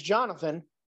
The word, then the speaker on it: Jonathan: Hey, judge, my name's Jonathan.